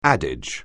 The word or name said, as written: adage